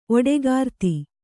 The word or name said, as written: ಓಡುಗರತಿ